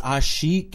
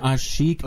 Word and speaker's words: Asik: I think it's Asik. – Asik? – Omer Asik.